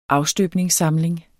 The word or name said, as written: afstøbningssamling